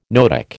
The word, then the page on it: noetic: American English